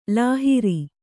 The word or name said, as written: ಲಹರಿ